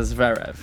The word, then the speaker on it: Zverev: Alexander Zverev.